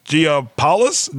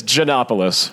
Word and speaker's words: Giannopoulos: All right, so on the Nothing Important Super Celebrity Hotline, we have the Fail Scouts, Alex Giapoulos. Did I pronounce that correctly? – No. – All right. Okay. Off to a good start. – Let me stop you there. Let's get proper pronunciations down and then just record us saying. – I kind of like the fact that I botched it, and he was like, "No." – Okay. No, it's Giannopoulos.